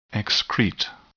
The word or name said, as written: excrete